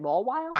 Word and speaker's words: Mawile: Is that how you say it? Mawile? – I have no idea. – I think that was it, yeah.